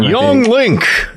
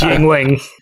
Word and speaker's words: Yuengling: Yuengling? I've been saying it wrong this whole time. – Yuengling! – Yeah, it's Yuengling. – Yuengling.